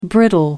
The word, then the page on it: brittle: American English